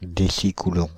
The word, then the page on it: décicoulomb: Paris; [de.si.ku.lɔ̃]